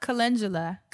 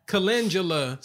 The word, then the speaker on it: Calendula: Calendula. – Calendula.